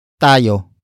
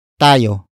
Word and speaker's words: tayo: The next word is tayo. Tayo. Tayo. Tayo is spelled as T-A-Y-O.